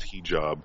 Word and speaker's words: hijab: Zach says it's pronounced hijab.